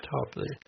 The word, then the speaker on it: Toplady: Yeah, that's his name, Toplady. I looked up the pronunciation. I thought maybe, you know, he might have pronounced it Toplady or something.